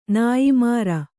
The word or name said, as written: ನೇಯ್ಮಾರು